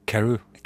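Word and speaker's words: Carew: Jeg tenker på Karev som egentlig uttales Karu.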